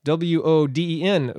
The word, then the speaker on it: Woden: Wednesday, if you ever wondered why it's spelled so weird, it's named after Woden, W O D E N, Woden.